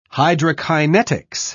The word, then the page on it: hydrokinetics: British English